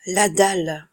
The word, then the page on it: la dalle: French